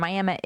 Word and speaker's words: Miami: And so there's the biggest town in Ottawa County is Miami, and it's spelled like Miami, Florida, but pronounced Miami, and I'm from that town.